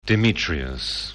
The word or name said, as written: Demetrius